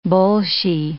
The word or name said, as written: bolshie